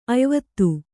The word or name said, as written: ಅಯಿವತ್ತು